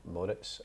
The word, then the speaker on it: Moritz: He did tell me, actually, when he signed, he pronounced his name Moritz, as in San Moritz.